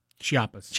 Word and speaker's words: Chiapas: Chiapas, near the Guatemala-Mexico border. – Chiapas. – Chiapas, thank you.